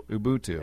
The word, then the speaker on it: Ubuntu: Because it's Ubuntu.